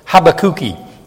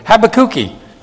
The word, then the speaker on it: Habakkuki: They said, yes, they pronounce it Habakkuki. Sounds like have a cookie, but Habakkuki.